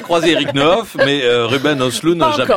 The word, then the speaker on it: Östlund: Contrepoint à lire cette fois dans Libération sous la plume de Julien Gester qui dénonce, lui, la farce lourdingue de Ruben Östlund. Un mot sur la prononciation? Non, non. – On dit bien.